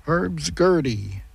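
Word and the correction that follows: Hurdy Gurdy: Did I say that right? No, Herdy Gurdy.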